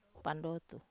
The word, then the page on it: pandotu: wamesa